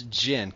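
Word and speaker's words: Jyn: Is it Jane Erso or Jan Erso? – I think it's Jin. Because Jin. Is it pronounced Jin? – Yeah.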